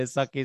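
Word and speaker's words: sakkie: It would have sucked to be a sucky, sucky musician.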